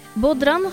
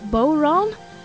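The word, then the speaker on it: bodhrán: Bodran, som eftersom det är ett keltiskt ord inte uttalas som det stavas utan istället lär ska uttalas Bowron, är en sorts trumma med ett helt underbart ljud.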